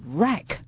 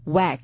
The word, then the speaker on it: wreck: 注意它的读音是 wreck, 不是 whack.